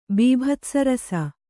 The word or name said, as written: ಬೀಭತ್ಸರಸ